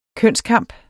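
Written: kønskamp

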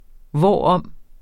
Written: hvorom

